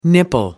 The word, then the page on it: nipple: British English